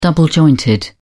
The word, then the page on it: double jointed: British English